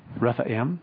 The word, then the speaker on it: Rephaim: Rephaim is how you say it.